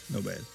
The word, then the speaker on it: Nobel: La scoperta verrà a Clyde Cowan e Fred Reines, il primo Nobel. O Nobel? – Nobel, c'era l'accento su lei.